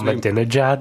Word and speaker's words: Ahmadinejad: The president of Iran, whose name I won't even attempt to pronounce, is supposed Ahmadinejad.